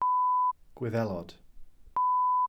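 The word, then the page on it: Gwyddelod: Welsh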